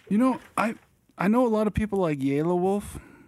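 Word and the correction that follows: Yelawolf: It's Yella Wolf. – Yella, is it? – Yeah.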